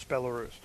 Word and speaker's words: Belarus: So, Belarus, is that how you say it? – Belarus.